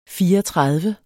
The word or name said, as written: fireogtredive